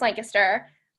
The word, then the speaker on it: Lancaster: And a girl asked me, like, I like told her I was from Lancaster, and by the way, it's pronounced Lancaster.